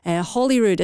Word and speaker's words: Holyrood: Holyrood as in holy, not Holyrood as in holly tree, so Holyrood.